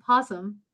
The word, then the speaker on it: opossum: Opossum because he has an O. I don't know why people pronounce it possum.